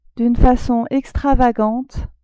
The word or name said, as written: d'une facon extravagante